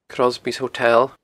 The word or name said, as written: Crosby's Hotel